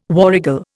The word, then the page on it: warrigal: Indian